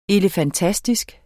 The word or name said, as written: elefantastisk